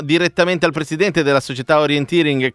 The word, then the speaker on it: orienteering: Dici l'accento per prima cosa, come si dice? – Orienteering.